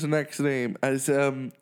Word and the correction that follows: Snoop Dogg: Snoop Dogg.